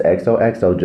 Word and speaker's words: XOXO: It's XOXO, just like you would sign, you know, at the end of a love letter, because that's kind of the theme of the show that we're going for.